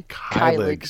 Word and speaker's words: Keilig: Teacher in Zion, and I have with me Kathy, and I'm always slaughter people's pronunciations with the last name, so Keilig, is that right? – Keilig.